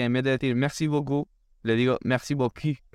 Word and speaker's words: beaucoup: La típica palabra merci beaucoup, eh, que significa muchas gracias. en francés, si pronuncias mal la palabra, puedes decir algo completamente diferente. Y es si dices merci beaucoup Boku significa buen culo.